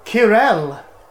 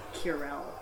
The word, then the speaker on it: Qirel: Kirel! From the Empirical Legion. – Kirel. – I spell that. It sounds like a hound. – Q-I-R-E-L. – Q-I-R-E-L.